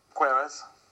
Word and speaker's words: Queiroz: Queiroz.